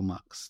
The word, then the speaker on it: Mx: MX, pronounced mix or mux.